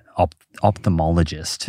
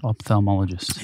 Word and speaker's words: ophthalmologist: You made the error that everyone makes. OPH. – OPH. – Folks, get that right. – Okay. – Ophthalmologist. – Yeah. – Silent H.